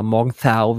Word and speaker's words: Morgenthau: Also Simo, ähm, die, die Kali Morgenthau oder Morgenthau, wie sie immer so schön mit TH ausgesprochen wird,